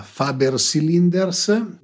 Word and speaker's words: Faber Cylinders: Bene, ringrazio Giovanni Tofoluti della Faber Cylinders, non so se lo pronuncio bene come lo pronunciate voi in italiano, in inglese, eccetera. – Ah, no, la pronuncia è perfetta, eh, questo è il nostro logo.